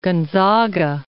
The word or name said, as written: Gonzaga